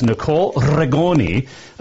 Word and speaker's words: Nicole Rigoni: Her name is Nicole Regoni.